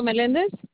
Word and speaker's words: Melendez: Rocio Melendez. – Can you spell it for me? – First name is R-O-C-I-O, and the last name is Melendez, M-E-L-E-N-D-E-Z.